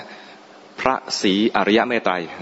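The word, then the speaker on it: พระศรีอริยเมตไตรย: ต้องออกเสียงชัดๆนะพระศรีอริยะเมตไตรพระศรีอริยะเมตไตรคนไทยเรียกพระศรีอานที่บอกให้ออกเสียงชัดๆเพราะบางทีกลายเป็นภาษีอาน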